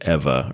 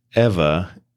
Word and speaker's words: Eva: It's E-V-A, but it says in the book that it's pronounced like Eva or something. It's not Eva.